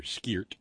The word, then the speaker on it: skeert: That's how we pronounce it here, skeert.